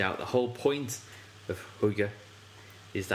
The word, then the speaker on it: hygge: Togetherness and social cohesiveness is also an underlying theme of Hygge.